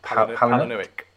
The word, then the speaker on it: Palahniuk: Palahniuk. – Palahniuk.